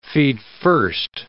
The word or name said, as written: feetfirst